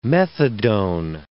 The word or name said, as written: methadone